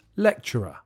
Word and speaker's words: lecturer: The answer is lecturer. The pronunciation is not difficult. It's interesting because the CT in the middle becomes a CH sound when you speak quickly.